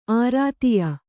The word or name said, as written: ಆರಾಧ್ಯ